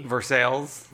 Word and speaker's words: Versailles: It's spelled Versailles, but I think it's literally said Versailles or something, Kentucky. – Versailles. – Versailles. Versailles is how they say it.